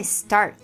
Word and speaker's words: start: A pronúncia dessas palavras em inglês é start, print, né, mad. Deu pra perceber como ficam leves, né, o T e o D no fim dessas palavras. Uhum.